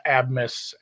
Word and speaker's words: Abmas: And we've had chaos, but you have, I never know how to say the guy's last name, but Max Abmas. It's so hard. It's like ace in the hole, but it's A-B-M-A-S. It's Asmus.